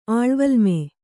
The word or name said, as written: ಆಳ್ವಲ್ಮೆ